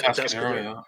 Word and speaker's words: Atascadero: Atascadero, have I pronounced that right? – Atascadero, yeah.